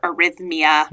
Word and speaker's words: arrhythmia: And then she says, What is erythemia, which unfortunately is a mispronunciation. There's no vowel between the TH and the M of arrhythmia.